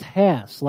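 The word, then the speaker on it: Hass: Actually, it's pronounced Haas. Like Haas. Oh, okay.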